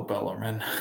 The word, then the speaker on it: Bellarmine: Oh, Bellarmine.